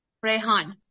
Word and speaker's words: Rehan: So yeah, I just want to ask you, how do you say your name? Rehan? – Rehan. – Rehan. Okay, Rehan. Thank you so much.